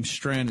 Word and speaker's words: Strachan: And I think that even though you pronounce his name Strand, it's S-T-R-A-C-H-A-N, Strachan, Owen Strand. I think that the C-H is silent, but it's Owen, S-T-R-A-C-H-A-N.